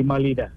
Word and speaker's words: malida: And you make, the halwa and the Medida. – The malida. – Malida is the pronunciation? – Yes.